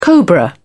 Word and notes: cobra: British English

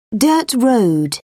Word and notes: dirt road: British English

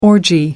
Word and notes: orgy: American English

